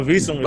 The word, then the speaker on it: Vilson: Yeah, well, Vilson is fine, but Vilson.